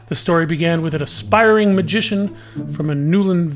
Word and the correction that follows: Nelwyn: village, I think I said that right, Nelwyn village,